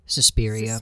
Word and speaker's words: Suspiria: Suspiria.